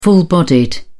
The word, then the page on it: full-bodied: British English